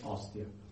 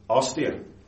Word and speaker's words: Ostia: And how do you say it? – Ostia. – Ostia.